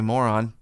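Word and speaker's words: Moran: Senators Roger Marshall and Jerry Moron, that's how his family pronounces it, met in the U.S.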